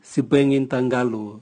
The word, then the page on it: sibwengin tangalu: Raga